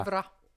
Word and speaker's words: плевра: Плевра. Ударение на последнюю «а».